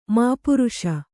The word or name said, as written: ಮಾಪುರುಷ